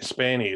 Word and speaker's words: Spaeny: Uh, Cailee Spaeny. Uh, what... Sp- Spaeny, yeah, that's how oh. I, I pronounce it.